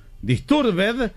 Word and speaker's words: Disturbed: No hay ninguna palabra en inglés que al final se pronuncie ed. Disturbed se pronuncia disturb.